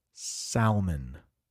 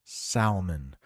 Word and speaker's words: salmon: Because that L in the middle is silent. At least where I live, the L is silent. If you go to the southern United States, states like Georgia, Alabama, Mississippi, you might hear this pronounced salmon. Salmon. But where I live, and I think most of the United States, they will pronounce it salmon.